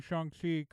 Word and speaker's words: Shang-Chi: So the creators aren't getting paid, but we at least are now pronouncing Shang-Chi correctly as a society, because everybody knows that even the people who created the character are probably saying Shang-Chi this whole time.